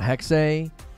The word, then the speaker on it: Hexe: I thought it was Hex, not Hexe. They were pronouncing it Hexe or Hexe. H-E-X-E. The final E is being pronounced, I believe, is like Hexe.